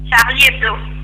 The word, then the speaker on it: Charlie Hebdo: Chaib Hebdo. Charlie Hebdo. ¿Cómo es que se pronuncia, ya que estamos acá? – Charlie Hebdo.